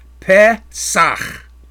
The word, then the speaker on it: Pesach: Now the Hebrew word here is Pesach. Pesach. The Strong's number is 6453. The Pe is in short E sound as in bed. Pe. Pesach.